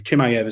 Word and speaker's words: Chimaev: Is it Chimaev? Chimaev is that? Yeah, I can Chimaev, never pronounce yeah.